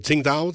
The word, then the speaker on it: Tsingtao: Deuxième partie de cette rubrique à chaud avec Joël Baumgartner, un Suisse qui vit en Chine, dans la ville de Tsingtao, c'est bien ça? – C'est exactement ça, tu prononces super bien.